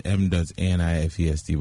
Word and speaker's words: Manifest: So it's M.A.N.I.F.E.S.T., but I still pronounce manifest.